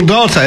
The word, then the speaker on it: Daawta: which is called Rebel Daughter. If you want to search it out, it's Rebel, and the word daughter is spelled D-A-A-W-T-A, and that's Rebel Daughter.